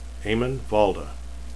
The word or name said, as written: valda